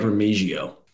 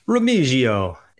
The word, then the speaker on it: Remigio: Wide receiver Nikko Remig- Remigio. How's the pronunci- pronunciation there? – I like Remigio. – Remigio.